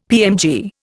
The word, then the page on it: PMG: Indian